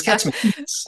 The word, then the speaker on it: Katsimatitis: Cathy, you pointed out I had Katsimatitis, like rhyming with gastritis. And you said, he's Katsimatitis. And we had to change the lines a little.